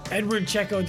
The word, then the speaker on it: Eduard Checo: It's Edward Checo.